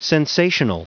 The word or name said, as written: sensational